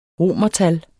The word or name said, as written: romertal